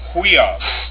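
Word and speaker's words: huios (υἱός): The next one is upsilon, iota, pronounced we, as in huios, meaning son, in the New Testament.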